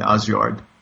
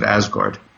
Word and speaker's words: Asgard: We know that because it says right there, under the rainbow, here is Valhalla and Asgard, or as people who don't read Norse pronounce it, Asgard.